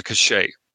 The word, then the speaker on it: cache: I just want to point out that You can always talk to Mariposa, and you forgot that there's a cachet or cache. Is it pronounced cachet or cache, guys? I think people pronounce them either way, but if it's a firewall, it should be a cache.